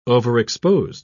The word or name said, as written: overexposed